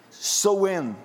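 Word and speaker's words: Samhain: Um dos mais expressivos dos sabás é o Soen, é assim que se pronuncia realizado em 31 de outubro, o famoso Halloween, o Dia das Bruxas.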